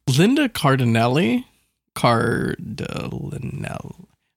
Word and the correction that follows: Cardellini: How do you say that? – Cardellini. – Cardellini.